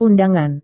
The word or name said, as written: undangan